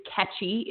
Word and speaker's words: Nketchi: Her name is Ketchy, is the first name. It's spelled N-K-E-T-C-H-I,